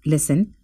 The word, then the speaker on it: listen: K is silent in the word knife, listen. T is silent, write. W is silent, high.